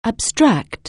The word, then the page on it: abstract: anglais